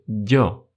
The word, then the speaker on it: yo: Например, «я» на испанском языке будет «дё». Прошу вас уходить от произношения «ё», как если бы это была и краткая.